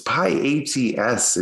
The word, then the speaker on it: pyATS: but it's pyATS, if you could just maybe get the name right.